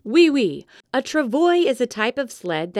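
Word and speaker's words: travois: What is a travoyus? – It is pronounced travoy.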